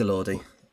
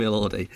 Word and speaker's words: Elordi: It is Elordi. He said, "G'day, I'm Jacob Elordi."